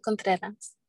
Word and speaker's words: Contreras: Contreras.